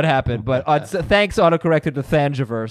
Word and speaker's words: Thanjavur: It's THANJAVUR.